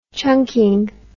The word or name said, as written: chunking